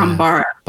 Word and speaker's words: Bambara: B-A-M. I wanted to spell it. – B-A-M-B-A-R-A. – Yeah. – Bambara.